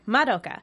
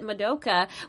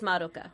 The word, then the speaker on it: Madoka: You said it right, by the way. A lot of people pronounce it Madoka, which is, you know, fair enough. That's what it looks like it should be, but it is Madoka.